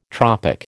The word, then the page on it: tropic: American English